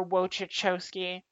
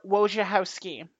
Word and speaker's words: Wojciechowski: And I'm sorry to say that about former Blue Jays prospect Asher Wojciechowski. I'm not saying that right. Let me try that again. Asher Wojciechowski. There we go.